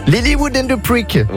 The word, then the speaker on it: Lilywood and the Prick: Lilywood and the Prick.